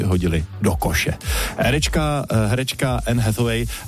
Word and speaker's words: Hathaway: Teď ale něco pro vás, kdo jste fanoušci americké herečky, nádherné americké herečky Anne Hathaway, Hathaway, takhle ji vyslovují, a filmu Deník princezny.